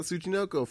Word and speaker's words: Tsuchinoko: Tsuchinoko, fuck. TSU, typically, you don't pronounce the T, and it's just Yeah, I didn't.